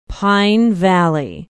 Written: pine valley

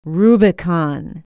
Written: Rubicon

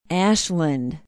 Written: ashland

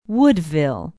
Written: woodville

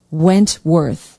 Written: wentworth

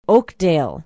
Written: oakdale

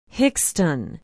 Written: hixton

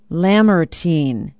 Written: lamartine

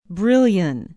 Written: brillion

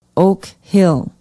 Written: oak hill

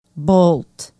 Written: bolt

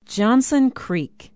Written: johnson creek

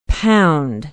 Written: pound

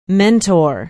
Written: mentor

